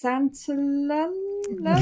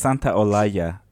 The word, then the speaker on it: Santaolalla: I've got Google has told me it's Gustavo Santaolalla. – Give it to us. – Santaolalla.